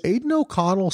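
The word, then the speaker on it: Aidan O'Connell: Is Aiden O'Connell still the quarterback, right? – Oh my God, you said it right.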